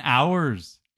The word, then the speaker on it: HRS: And Hours is a real song. That song is really good. It's spelled HRS, is. – HRS. So I was like, hers and hers?